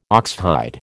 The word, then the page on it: oxhide: American English